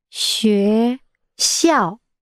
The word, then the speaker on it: 学校: How do you say school? – Shui Xiang. – Xue Xiao.